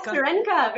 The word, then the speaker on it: Sirenka: I'm a huge fan of your work on Cuphead, on Sirenka. Is that how you say it? – Sirenka? Yes, Sirenka. Very Sirenka. Good.